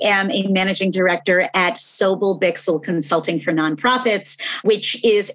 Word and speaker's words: Sobel Bixel: A managing director with Sobel Bixel. Did I say that right? – Yeah. Sobel Bixel.